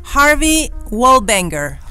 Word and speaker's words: Harvey Wallbanger: Y decirlo porque es un trago en inglés, ¿cómo se pronuncia Ay, me quiero morir. – Harvey Wallbanger. – Ah, Muy bien.